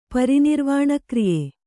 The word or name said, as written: ಪರಿನಿರ್ವಾಣಕ್ರಿಯೆ